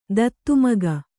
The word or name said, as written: ದತ್ತುಮಗ